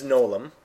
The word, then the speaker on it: Gnolaum: Is Nolum. – Okay. Can you spell that for with a silent G. – Okay. Yes. – As complicated as it can be. – G-N-O-L-A-U-M.